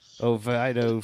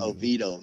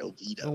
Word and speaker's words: Oviedo: He's going to run it under Base Camp, Anytime Fitness, Oviedo, Florida, wherever the fuck you... – Oviedo, man. Yeah. Say it right, Oviedo.